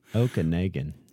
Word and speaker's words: Okanagan: But according to the Vancouver Sun, the central, how do you say that? Okanagan search and rescue crew. – Okanagan. – Okanagan? – Depending where you're from.